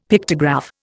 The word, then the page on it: Pictograph: Indian